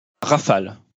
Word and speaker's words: Rafale: Rafale. – Well, I can't quite put the French pronunciation on like you do, but it's Rafale or something along those lines.